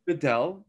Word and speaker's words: Bedell: Bedell.